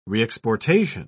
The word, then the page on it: reexportation: British English